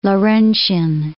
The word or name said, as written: Lawrentian